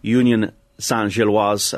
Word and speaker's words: Union Saint-Gilloise: So on Sunday, Union Saint-Gilloise, as Cameron has thankfully informed me how to say it properly, were about to win the Belgian title.